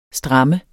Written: stram

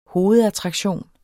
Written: hovedattraktion

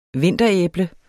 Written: vinteræble

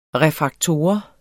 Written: refraktor

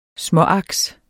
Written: småaks

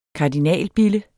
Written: kardinalbille

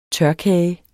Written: tørkage

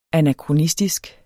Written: anakronistisk

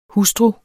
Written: hustru

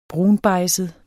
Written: brunbejdset

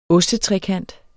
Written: ostetrekant

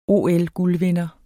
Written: OL-guldvinder

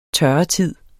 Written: tørretid

